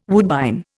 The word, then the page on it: woodbine: Indian